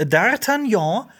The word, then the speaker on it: D'Artagnan: Die drei Musketiere, Doppelpunkt D'Artagnan.